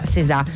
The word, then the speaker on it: SZA: Sicuramente, perché lei è una delle rapper più amate del momento, scritta S-Z-A, si pronuncia César.